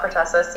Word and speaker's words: pertussis: Whooping cough, pertussis.